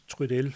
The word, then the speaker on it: Trudel: Og også derfor jeg er ret sikker på, at han skal udtales Trudel, fordi han har skrevet det meste af sit forfatterskab på fransk.